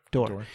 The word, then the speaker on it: Dore: I more mean, like, what is the psychology of a woman who comes up to Jimmy Dore how do you say his last name? – Dore.